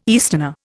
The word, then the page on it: easterner: Indian